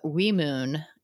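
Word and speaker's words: We'Moon: It's called Wee Moon. It's spelled W-E-apostrophe-M-O-O-N.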